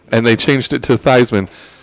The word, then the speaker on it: Theismann: Joe Theismann's name is not pronounced Theismann. Did you know that? – What? – It's Theismann. And they changed it when he was in college so they could run a Heisman campaign for him. And to this day, everybody calls him Joe Theismann, and that is not how you pronounce his last name.